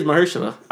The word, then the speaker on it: Mahershala: You said his name wrong, but yeah. No, Mahershala? It is Mahershala Ali. – Yo, okay, now you said it right.